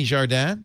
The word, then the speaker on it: Jardin: Then from Boing Boing, I could say that, Shenny Jardin.